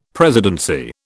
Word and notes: presidency: British English

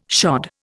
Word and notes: shod: Indian

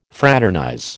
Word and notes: fraternize: American English

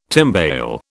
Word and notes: timbale: British English

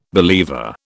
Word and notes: believer: British English

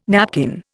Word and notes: napkin: Indian

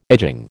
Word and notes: edging: American English

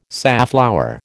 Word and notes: safflower: American English

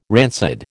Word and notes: rancid: American English

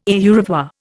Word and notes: au revoir: Indian

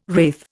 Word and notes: wraith: Indian

